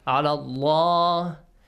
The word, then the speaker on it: Allah: So the rule says if the word Allah is preceded by Kasra. – A kasra. – Yes. – It should be pronounced? – Lightly. – La. But if it is preceded by? – Fatha. – Or? – Dhamma. – These two vowel sounds. It should be pronounced? – Heavily.